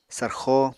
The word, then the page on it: sarxo: apatani